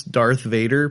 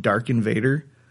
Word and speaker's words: Darth Vader: I couldn't pronounce Darth Vader properly. I kept calling him Darken Vader.